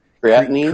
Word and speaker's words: creatinine: Creatinine. Wait, am I saying it right now? – Creatinine. – Creatinine. I always want to say like creatine with an extra syllable.